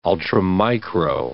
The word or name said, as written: ultramicro